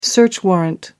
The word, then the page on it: search warrant: American English